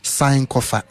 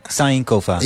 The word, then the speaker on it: Sankofa: Sankofa. Dat is... – Zo spreek je het uit. – Ja, zo zeg je het eigenlijk. Sankofa. – Sankofa.